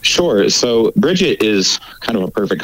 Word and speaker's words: Brigid: Well, that's kind of a tough question to answer, because there's sort of a standard way of pronouncing the name in English, and it's totally different than how it's pronounced in Irish or in Scots Gaelic. So probably most people in English say Brigid, uh-huh. and then in Irish it's usually some version of Brigid or Brigid.